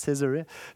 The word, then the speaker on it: Caesarea: Is that how you say it? Caesarea? Philippi? Thank you.